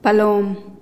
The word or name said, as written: palom